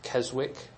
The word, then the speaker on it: Keswick: spelled Keswick, if you've read about it, but it's pronounced Keswick, don't ask me why.